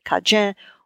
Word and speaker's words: Cadien: We wanted it to, we were tired of seeing this C-A-J-U-N. It's, it, it leads to mispronunciation. So we kind of came up with the C-A-D-I-E-N from acadien, but we're going to pronounce it, acad, you know, pronounce it kajin.